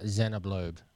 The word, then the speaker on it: Xenoblade: Xenoblade.